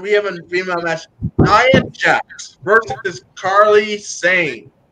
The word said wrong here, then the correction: Kairi Sane: Now, have I got that name right? – I tell you this, it's pronounced Kairi Sane.